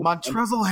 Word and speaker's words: Montrezl: Is it Montrezl? – Montrezl Harrell.